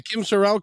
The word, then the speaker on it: Sorrelle: Kim Sorrelle correct? – Yes, Sorrelle's perfect.